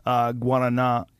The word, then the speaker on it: guarana: Excuse me, he got perfect Portuguese pronunciation. – Yeah, guarana.